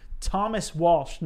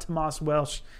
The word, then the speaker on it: Thomas Walsh: Thomas Walsh, not Tomas Welsh.